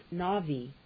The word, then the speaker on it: Nabi: Prophet, Nabi, N-A-B-I, pronounced Navi, Strong's Concordance number 5030.